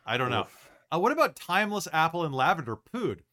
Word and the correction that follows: pud: P-U-D. P-U-D food? – What does pood mean? – Like, oh, it's just pudding. It's just short for pudding. – Oh, it's just pud.